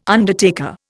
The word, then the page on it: undertaker: Indian